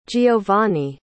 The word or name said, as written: geovany